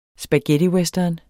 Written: spaghettiwestern

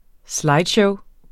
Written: slideshow

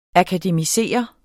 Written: akademisere

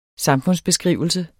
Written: samfundsbeskrivelse

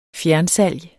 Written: fjernsalg

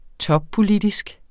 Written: toppolitisk

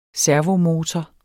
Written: servomotor

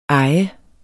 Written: eje

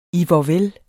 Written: ihvorvel

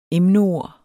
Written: emneord

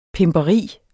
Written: pimperi

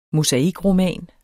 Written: mosaikroman

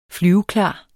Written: flyveklar

